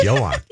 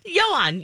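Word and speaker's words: Ioan: But it's spelled IOANGRUFFUDD. So there you go. If you see that name, that's how you pronounce it. – It's Johan. – Johan, yes.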